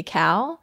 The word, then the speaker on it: Calle: What's it, C-A-L? – C-A-L-L-E. – C-A-L-L-E. – I think it's pronounced Cal.